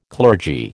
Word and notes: clergy: American English

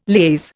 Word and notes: liaise: Indian

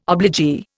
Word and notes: obligee: Indian

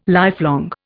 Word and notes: lifelong: Indian

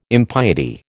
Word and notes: impiety: American English

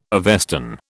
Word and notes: Avestan: British English

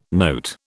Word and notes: note: British English

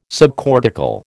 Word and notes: subcortical: American English

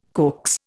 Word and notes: coax: Indian

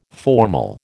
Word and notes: formal: American English

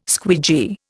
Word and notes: squeegee: Indian

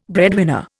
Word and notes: breadwinner: Indian

